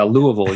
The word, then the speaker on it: Louisville: So yeah, Louisville, you just kind of got to swallow it all.